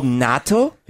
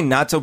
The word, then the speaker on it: natto: Natto is, and real Japanese people are pronounced natto a little differently, but Americanized say natto. But anyway. – Well, I say natto because, you're right. – How should you say it? – It's just, it has an accent. Natto. Has some kind of weird consonant in there.